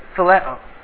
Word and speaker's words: phileo: And the last one is phileo. Not phileo fish, but it's kind of spelled like that, P-H-I-L-E-O. And it's a brotherly kind of love.